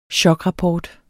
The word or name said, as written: chokrapport